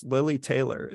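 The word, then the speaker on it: Lili Taylor: So a brilliant thing that they do in The Conjuring is they cover, sorry, I mispronounced her name before. It's Lily Taylor.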